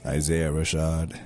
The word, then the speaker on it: Isaiah Rashad: Isaiah Rashad.